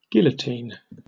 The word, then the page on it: guillotine: British English